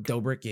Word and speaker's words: Dobrik: I think it's Dobrik. – Dobrik.